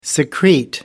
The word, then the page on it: secrete: American English